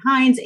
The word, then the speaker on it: Hines: and so, like, if I check in somewhere, I'll say Hines, H I or H I N E S.